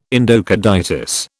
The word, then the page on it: endocarditis: British English